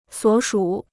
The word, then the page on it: 所属: suǒ shǔ